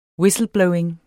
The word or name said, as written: whistleblowing